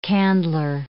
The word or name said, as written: candler